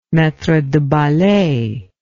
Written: maitre de ballet

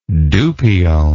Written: dupion

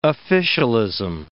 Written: officialism

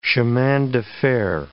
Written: chemins de fer

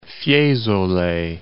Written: Fiesole

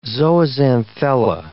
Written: zooxanthella

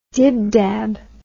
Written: dibdabs

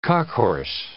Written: cockhorse

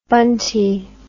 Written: bunty

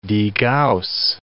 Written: degauss

